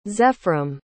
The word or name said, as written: zefram